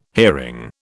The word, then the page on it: hearing: British English